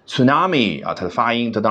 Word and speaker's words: tsunami: tsunami 啊啊 e u a o 啊，日语当中有个比较发啊奇怪的这个发音叫 u 啊，英文当中呢，它只能用相似的那个 u 啊来代替。